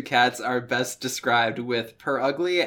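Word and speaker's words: Purugly: Purrugly. – Yeah, oh, okay. P-U-R-R ugly. – Literally what it sounds like. – Like coyote ugly, but with purr instead of coyote.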